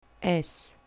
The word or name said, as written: s